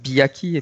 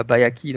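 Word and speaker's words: Byakhee: Maintenant, je suis perdu parce que j'ai entendu récemment un podcasteur américain qui disait ça Biaki et pas Bayaki, donc je ne sais plus quelle est la prononciation correcte. Bon, de toute façon, la réponse est qu'il n'y a pas de prononciation correcte.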